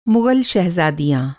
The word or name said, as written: शहज़ादी